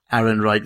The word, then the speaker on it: Ehrenreich: Ehrenreich, is that how I would say it? You were practicing before. How would you say his name? – Is it Alden Ehrenreich? – Ehrenreich, there you go.